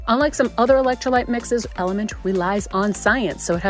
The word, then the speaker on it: LMNT: It's LMNT, pronounced Element.